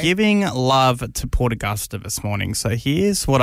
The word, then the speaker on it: Port Augusta: Port Augusta.